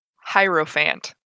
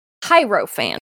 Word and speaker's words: Hierophant: And then their peak level 19 ability, and you guys will have to help me pronounce this. It's Primal Hierophant? – Hierophant. – Hierophant. Thank you.